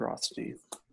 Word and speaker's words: Rothstein: Got the Color of Law by Richard Rothstein. – There you go. Hey, you pronounced it correctly.